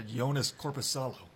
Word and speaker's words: Joonas Korpisalo: They lost to Joonas Korpisalo.